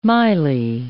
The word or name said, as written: maile